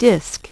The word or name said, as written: disk